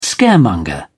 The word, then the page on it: scaremonger: British English